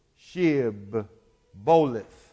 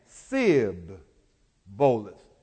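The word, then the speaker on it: Shibboleth: When you reflect your minds on Judges, the 12th chapter, when you look at those two words, Shib, Boleth, and Sib, Boleth, same amount of syllables, sound the same, but it was only one letter in that word that they couldn't pronounce, the H.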